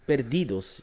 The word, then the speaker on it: perdidos: Eh, is het uh, Juguetes Peridos? Of perdidos. Juguetes perdidos, denk ja, ik.